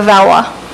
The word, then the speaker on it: Valois: It's Valois, and it rhymes with bourgeois, so Valwa.